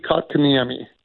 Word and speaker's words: Kotkaniemi: Yeah, Jesperi Kotkaniemi. – Kotkaniemi.